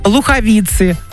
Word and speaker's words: Луховицы: У нас в России 3 столицы: Москва, Питер, Луховицы. Вот так надо запоминать ударение, я поняла вас.